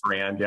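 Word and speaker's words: Farrand: My Ferrand, is it Ferrand? Is that pronounced it? My Ferrand records. – Ferrand, yeah. – Ferrand, sorry.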